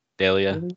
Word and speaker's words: Delia: I would have said Delia. – Delia. Yeah. Not like Dahlia, like the Black Dahlia. But like D-E-L-I-A.